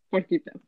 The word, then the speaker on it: Puerquita: nickname was Borquita. – Puerquita. Puerquita, like little piggy.